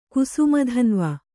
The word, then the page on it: ಕುಸುಮಧನ್ವ: kannada